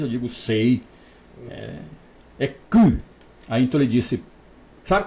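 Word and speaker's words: cou: Quem sabe um pouco de francês sabe que o pescoço em francês é cu. a pronúncia é essa, apesar de não escrever assim, sim, sim.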